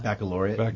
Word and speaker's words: Baccalaureate: Baccalaureate. How do you pronounce that? – Baccalaureate. – Baccalaureate. – Yeah, there you go.